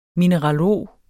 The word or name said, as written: MINERALOG